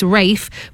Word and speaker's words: Ralph: He said, obviously, the traditional pronunciation of his name is Rafe, but it is spelt as Ralph.